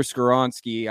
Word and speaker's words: Skoronski: Peter Skoronski, I think I pronounced that right. – Yep.